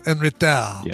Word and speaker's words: en retard: Probably, and that's another one, probably, where you don't pronounce the last consonants, right? So, en retard.